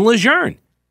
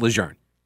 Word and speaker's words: Lejeune: It's not Camp Lejeune all these years. It's been Lejeune. And the family's like, yeah, you know, not for nothing, it's pronounced Lejeune.